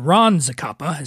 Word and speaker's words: Ron Zacapa: And again, everybody, check out Rum Zacapa, or Ron Zacapa is how you say it.